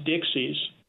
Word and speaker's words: Dix: Historically, there was a $10 bill in circulation called the Dix, D-I-X in French, but pronounced Dixies.